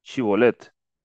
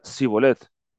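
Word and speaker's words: Shibolet: Si él respondía no, entonces le decían, ahora pues di Chibolet. Y él decía Sibolet, Entonces no podía pronunciarlo correctamente.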